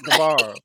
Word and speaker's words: Guevara: How you pronounce his name? – Guevara. – I'm like, wait, how do you pronounce it? – You're not the only one to be messing up with names. Like. – Sammy Guevara. Correctum.